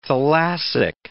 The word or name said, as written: thalassic